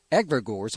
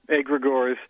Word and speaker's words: egregores: tell us a little about your new book, Egregores. Am I pronouncing that correctly? – Nobody gets it right, so don't worry about it. It's Greek. It's Greek. we say egregores, although some say egregores.